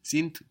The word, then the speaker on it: sind: And it's spelled S-I-N-D. And again, we have the hard version of the D sounding like a T. Sind. How was the word child? – Kind. – Kind. Again, K-I-N-D, but we hear a T.